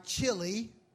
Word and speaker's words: chiliasm: Chiliasm, that's spelled C-H-I-L-I-A-S-M. C-H-I-L-I. Like chiliasm, but it's pronounced chiliasm. Chiliasm. The C-H is pronounced like a K.